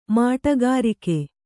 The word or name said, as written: ಮಾಟಗಾರಿಕೆ